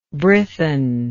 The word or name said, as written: Brython